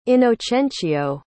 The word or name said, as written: inocencio